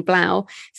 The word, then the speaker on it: 3LAU: I think you pronounce his name Blau, 3LAU.